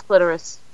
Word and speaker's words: clitoris: Do you pronounce it clitoris or clitoris? – It's clitoris.